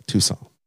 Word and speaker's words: Toussaint: It's not, there's no T. – Toussaint? – You don't pronounce the T in French. – That's right. Toussaint.